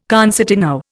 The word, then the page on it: concertino: Indian